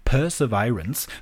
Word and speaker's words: Perseverance: Ich sage euch, wie es richtig ausgesprochen wird. Es heißt nämlich Perseverance. Ne? Ich mache es jetzt nochmal ohne das laute P-Laut. Perseverance.